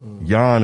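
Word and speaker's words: Jan: Wrote clarification: J in German is pronounced like a Y. – What? – So the name. Yawn in German is pronounced like yawn in English. Yawn. I said jag or something like that.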